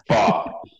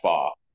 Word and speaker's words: Fach: So it's spelled F-A-E-H, so Fatch, but it's pronounced Faw.